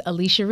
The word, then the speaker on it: Alechia: Listen, you can find me all over Beyonce's internet at Alicia Reese, and it's spelled A-L-E-C-H-I-A, last name Reese, R-E-E-S-E.